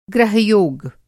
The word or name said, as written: ग्रह-योग